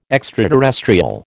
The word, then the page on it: extraterrestrial: American English